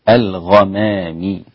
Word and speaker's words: الغمام: الغمام، الغين حرف مفخم ما ينفعش أقول الغمام، الغين لا تنطق غاء تنطق غاء، الغمامي هكذا.